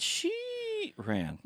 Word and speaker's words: Sheeran: So if you're trying to look it up and you don't know how to spell his name, I found it's she ran. Well, sort of she ran. She with two e's. It's she ran. Yes, there you go.